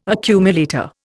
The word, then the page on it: accumulator: Indian